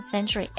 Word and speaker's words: sentry: 那我们把这个 century 的 c 变成 s 的话呢，读音没变， century 依然是 century，但是它的意思就变了。 century， S-E-N-T-R-Y，名词表示哨兵、岗哨的意思。